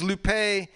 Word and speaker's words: Lupe: This is Lupe, L-U-P-E with the high accent on the E.